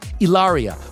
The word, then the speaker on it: Ilaria: Ilaria posted this new family portrait, and this morning she shared the sweetest pics of the newborn, whose first name is pronounced just like hers, Ilaria, which means happy.